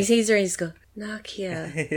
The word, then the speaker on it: Nokia: I think it's actually pronounced Nokia, and the Americans mispronounce it.